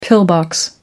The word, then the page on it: pillbox: American English